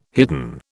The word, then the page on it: hidden: British English